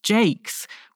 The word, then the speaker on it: Jaques: His name can be pronounced Jaques, which is Elizabethan slang for a toilet.